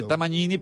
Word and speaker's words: Tamagnini: Sí, en italiano se dice Tamagnini, pero es GN, sí.